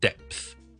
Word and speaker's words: depth: 所以我哋读埋个 P 音 depth depth depth depth depth，跟住直接 th depth depth depth。 OK，所以听多次 width width width the width is the width is，记住有个停顿，因为个 D 音好似 T 音咁。 width 同埋 depth depth depth P 音相唇合埋 depth 跟住先 th 出嚟 depth depth depth 好啦，希望解答到听众呢两字发音嘅问题啦。